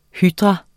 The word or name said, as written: hydra